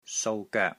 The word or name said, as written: SOU3 GOEK3